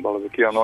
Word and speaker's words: Balabekian: Koliko sam uspio da vidim na probije, i jedan fudbaler iz jedan Jermenin, dakle Balabekian, al tako, ako sam dobro izgovorio. – Tako je, tako je, Arsen Balabekian, on je igrač kluba Banans, čiji je trener Stevica Kuzmanovski.